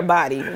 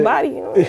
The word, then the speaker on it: Bodhi: Saint Bodhi, Saint Bodhi, Bodhi. Yeah, it's either Bodhi or Bodhi, one or the other. And I'm like, I'll take the Bodhi.